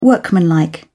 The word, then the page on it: workmanlike: British English